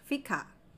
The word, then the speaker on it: fika: The second principle is fika.